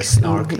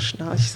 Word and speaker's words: Schnarch: Du hast auch auf deiner Website geschrieben, du hast unter oder mit oder bei David, wie spricht man ihn, Schnark gelernt. – Richtig. Oder Snark, in Deutschland sagt man einfach Schnarch sonst.